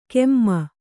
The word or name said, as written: ಕೆಮ್ಮ